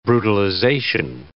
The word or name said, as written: brutalization